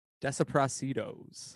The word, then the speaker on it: Desaparecidos: Desaparecidos.